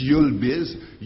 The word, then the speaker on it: YulBiz: It's YulBiz. YuL is the letter of the airport of Montreal, and Biz is for business.